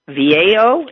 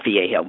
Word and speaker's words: Vallejo: Oh, here, Chris Platzer in Vallejo, is that how they, maybe that's not Vallejo, V-A-L-L-E-J-O, Vallejo? – Vallejo, but I mean, not in any way that it's usually spelled. – It's spelled V-A-L-L-E-J-O. – Well, that would be Vallejo. Yeah, Vallejo. Yeah.